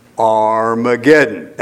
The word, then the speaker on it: Armageddon: The way we pronounce this is what? Armageddon.